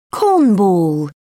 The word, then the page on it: cornball: British English